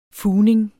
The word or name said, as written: fugning